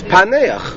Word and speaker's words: Paneach: It's Paneach. – Vedeshva, so it's a silent Ein. It's not Paneach, it's Paneach. I mean, it's with a Shva, so you can't pronounce it any other way.